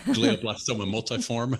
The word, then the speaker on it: glioblastoma multiforme: Glioblastoma multiforme.